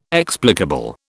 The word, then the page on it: explicable: British English